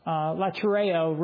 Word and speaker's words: latreo: Latreo really is the way it's pronounced.